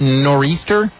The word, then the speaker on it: Nor'easter: It says, taxpayers in the Northeast who are affected by the, and it's spelled this way, NOR-EASTER. Is that Nor'easter? – Nor'easter, yeah.